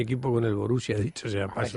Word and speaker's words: Borussia: No, la pronunciación Borussia es Borussia. Borussia. Hay dos.